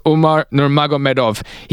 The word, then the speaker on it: Umar Nurmagomedov: Let me get this right. I always get a confusion with this pronunciation. It's Umar Nurmagomedov.